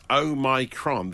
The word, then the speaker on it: Omicron: Well, uh, well, apparently due to the Greek alphabet, the experts are saying that Omicron, the emphasis on the my, is apparently the right oh.